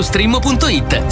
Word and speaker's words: Streammo: Vai su strimmo.it. Si scrive Streammo o scarica l'app.